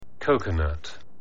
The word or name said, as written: coconut